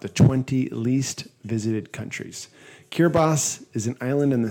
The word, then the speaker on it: Kiribati: It is pronounced Kiribati, although it is spelled Kiribati.